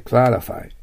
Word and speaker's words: clarify: clarify、 R の発音があるので最初が L ですから clarify となります。